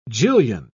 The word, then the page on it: jillion: British English